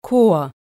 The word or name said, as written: Chor